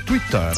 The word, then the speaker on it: Twitter: No, avui el Twitter, que si ho pronunciem en català oriental i fem les vocals neutres, hem de dir Twitter i no Twitter.